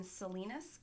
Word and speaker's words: Salinas: Jodi was born July 9th, 1980 in Salinas, California. Is that pronounced right? – I believe so, yes.